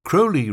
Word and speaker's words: Crowley: Crowley rhymes with holy, which was apt, since his Quaker parents had joined a fundamentalist Protestant sect and become Plymouth Brethren.